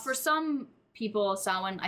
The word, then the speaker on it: Samhain: The next is Samhain, which is pronounced Samhain.